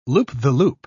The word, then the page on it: loop-the-loop: British English